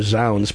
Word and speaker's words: Zounds: And I would imagine the trade name Zounds, part of it, of course, it rhymes with sounds.